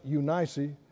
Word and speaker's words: Eunice: which dwelt first in your grandmother Lois and your mother Eunice, some pronounce it Eunice, I'm persuaded that in you also.